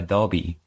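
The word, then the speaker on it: Dobie: No, a nice Brownie is actually called a Dobby.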